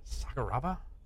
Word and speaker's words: Sakuraba: He's trying to pronounce Sakuraba's name. He's like, Tsukurubu. and then I think Brian Stan whispers over, it's Sakuraba? – Yeah.